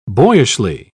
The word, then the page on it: boyishly: British English